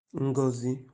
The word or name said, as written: Ngozi